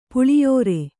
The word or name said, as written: ಪುಳಿಯೋರೆ